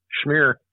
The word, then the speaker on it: Schmehr: S-C-H-M-E-H-R. Schmier? – Schmier.